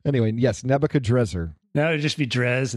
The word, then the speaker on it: Nebuchadrezzar: Yeah, that's, there, that's it's a there are a couple of ways to pronounce his name, and Nebuchadrezzar is hmm. – probably the more accurate one. But of course, Nebuchadnezzar is so popular, it's hard to run against it.